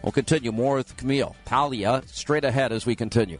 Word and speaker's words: Paglia: Camille Paglia is with us. And even though it's spelled P-A-G, it's Paglia.